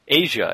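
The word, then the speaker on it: Asya: My favorite part of this email, as quirky as I am, at the end it says, sincerely, Asia, and it's A-S-Y-A, and then they put in parentheses, pronounced Asia like the continent or the band.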